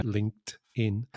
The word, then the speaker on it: LinkedIn: LinkedIn. Yeah, there's a strong T there.